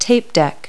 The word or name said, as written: tape deck